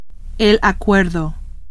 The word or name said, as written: acuerdo